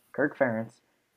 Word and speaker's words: Kirk Ferentz: Under Kirk Ferentz, is that how you say it? – Yeah, Kirk Ferentz.